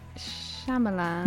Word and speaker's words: Shyamalan: how are you going to say his name? – Shyamalan. – Yes. – Correct.